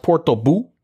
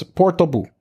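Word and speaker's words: Portbou: my understanding is it's pronounced Porto Bu. – Okay. – So Port Bu. It's Porto Bu.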